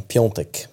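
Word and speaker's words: Piątek: Du, eh, Piotek. Visst uttalas det så? – Mm, Piontek. – Ah, okej, okay, förlåt. Det är ett, ett osynligt N i mitten. Ja.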